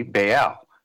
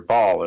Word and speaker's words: Baal: And, you know, part of the second tier gods in Canaan would be Baal, which most people are familiar with, or Baal, as some people might pronounce it.